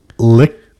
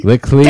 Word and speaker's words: Likkle: L-I-K-K-L-E space J-O-R-D-E-E. – I would say, I would pronounce it Lickle Jordi? – Lickle. – Damn it, dude. Anyways, Pana and L-I-K-K-L-E J-R-D-E-E.